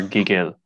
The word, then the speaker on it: gigil: Ooh, G-I-G-I-L. Mm-hmm. I'm going to put emphasis on the second part again. I'm going to say gigil. – Close. It's Gigil.